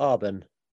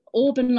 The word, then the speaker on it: Aben: Is it Aben or Arben? – It's Auburn like Bourbon.